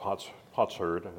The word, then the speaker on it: potsherd: I had to look up what potsherd, that's how you pronounce it, what that is.